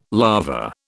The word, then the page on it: lava: British English